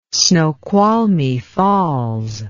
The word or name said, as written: Snoqualmie Falls